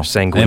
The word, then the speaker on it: sanguine: Sanguine. I think I'm saying that correctly. – Is it? Yeah, I don't know. – Sanguine. – In my brain, I'm like sanguine. – Sanguine. – It's one of those words you read and then you put it in your brain. – Sanguine, yeah. – Sanguine. – I was correct. – Close to penguin.